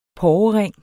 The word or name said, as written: porrering